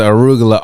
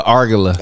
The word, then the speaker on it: arugula: That man, when that man first moved to LA, he is pronouncing arugula, argula.